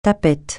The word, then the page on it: tapette: francais